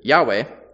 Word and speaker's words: Yahweh: The current pronunciation, if you're curious, Yahweh, comes from the fact that the shortened form that is seen at times in the Hebrew Bible is just the first part, the YH, pronounced as Yah.